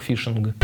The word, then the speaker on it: фишинг: Только если рыбалка пишется через F, английскую, фишинг, то этот вот компьютерный фишинг, он через PH, phishing, получается. Но читается одинаково, произносится как фишинг.